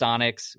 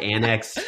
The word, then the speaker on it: Onyx: And it's pronounced Onyx. We have people who will literally like comment on our videos and be like, "Imagine mispronouncing the disc's name."